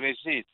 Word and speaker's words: Regis: Yes, my name is Regis, R-E-G-I-S.